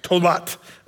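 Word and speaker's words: tolat (תולעת): The worm is a tola or a tolet, as this is pronounced. Pronunciation is like tola with a TH, which is like a T sound, tolat.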